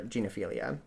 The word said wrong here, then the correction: gynophilia: It's G-Y-N-E. Sexuality. – Gynophilia. – There you go.